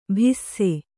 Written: ಭಿಸ್ಸೆ

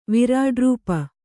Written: ವಿರಾಡ್ರೂಪು

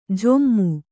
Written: ಝೊಮ್ಮು

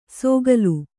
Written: ಸೋಗಲು

